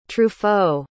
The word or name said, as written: truffaut